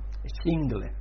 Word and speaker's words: Schindler: Me, me he preocupado de buscar la pronunciación en alemán, no vayáis a creeros que no, porque en inglés lo decimos de otra manera, pero en alemán, Schindler.